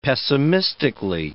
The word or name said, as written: pessimistically